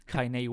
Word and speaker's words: Kanye: Kanye West.